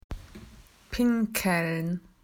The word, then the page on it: to wee: Australian English